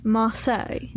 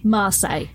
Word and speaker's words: Marseille: Marseille. – Marseille.